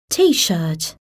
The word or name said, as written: T-shirt